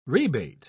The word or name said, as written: rebate